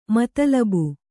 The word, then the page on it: ಮತಲಬು: kannada